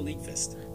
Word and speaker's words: Nyqvist: Michael Nyqvist.